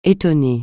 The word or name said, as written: étonner